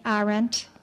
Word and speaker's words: Arendt: Am I saying that correctly, Arendt? Okay.